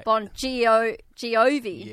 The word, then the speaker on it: Bongiovi: Well, it's spelled B-O-N-G-I-O-V-I. – Right. Yeah. – Bon Jovi.